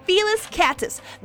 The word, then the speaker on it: Felis catus: Yeah, Felis catus. That's how you say its scientific name, bitches.